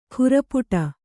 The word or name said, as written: ಖುರಪುಟ